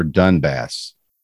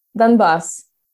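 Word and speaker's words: Donbas: is it Donbass or Dunbass? – Dunbass. – Dunbass. – Like the bus.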